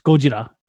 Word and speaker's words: Gojira: First of all, it's that's Japanese and it's pronounced Gojira.